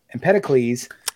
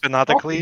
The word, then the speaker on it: Empedocles: So it wasn't until I'm going to say his name right, Empedocles uh, first. – Empedocles.